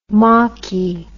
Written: mockie

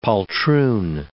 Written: poltroon

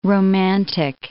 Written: romantic

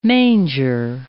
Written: manger

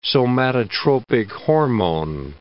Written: somatotropic hormone

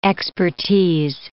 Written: expertise

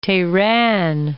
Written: Tehran

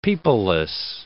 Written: peopleless

